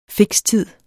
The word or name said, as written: fikstid